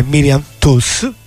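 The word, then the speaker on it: Toews: che è Miriam Tuss, così si pronuncia, l'ho scoperto dopo, perché no, ovviamente perché l'ho sentita nella sua, quando l'ha detto lei quando è venuta qui a Roma.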